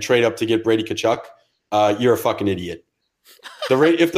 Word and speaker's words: Tkachuk: This is Brady Tkachuk. – Nope. – Tkachuk. – Tkachuk. – Tkachuk. – The T almost doesn't exist.